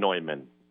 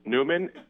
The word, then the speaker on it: Neumann: Neumann, and since John Neumann's father and mother were from Germany, I suppose they would have said that. – Okay. – They would have pronounced it as such. But since we're in the United States of America, and the entire parish here says it Newman, we'll say Newman.